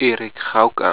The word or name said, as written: Erik Gouka